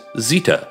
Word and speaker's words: zita: Dog would have been pronounced as zita, Z-I-T-A.